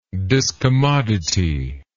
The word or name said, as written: discommodity